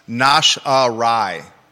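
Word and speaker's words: nosh: And it's pronounced nash-a-rye. Deep throat says. Nash-a-rye, the Yiddish word. Food you nash on. He says,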